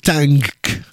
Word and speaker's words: Tangk: L'album Tank, alors c'est pas facile à dire, ça s'écrit T-A-N-G-K, sortira le 16 février prochain chez Partizan avec aussi le label Pias.